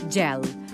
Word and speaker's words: gel: I, per cert, fixeu-vos en la pronunciació de la paraula gel, amb el mateix so que gener o gegant.